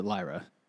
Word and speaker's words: Lyra: The fucking author pronounced it Lyra.